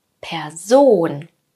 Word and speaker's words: Person: Wo ist die Betonung bei diesem Wort? Was denkst du? Auf der ersten oder zweiten Silbe. Person. Ich werde lauter und gehe mit der Stimme nach oben. Und zwar bei Ohn. Person. Hier liegt die Betonung.